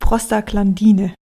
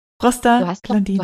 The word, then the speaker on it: Prostaglandine: Und was wir jetzt so noch nicht erwähnt hatten, sind, oh, dieses Wort ist für mich so schlimm, Prostaglandine. – Prostaglandine. Du hast doch richtig ausgesprochen jetzt.